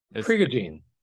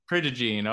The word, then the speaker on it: Prigogine: Prigogine, Prigogine. – Prigogine.